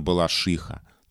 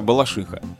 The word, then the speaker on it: Балашиха: И словари для работников СМИ, словари имен собственных говорят о том, что правильно балашиха. То есть то, как произносят местные.